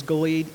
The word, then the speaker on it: Gilead: The proper pronunciation is Gilead, even though the song says Gilead. So if that's your pronouncing Gilead, that's fine, but it is Gilead.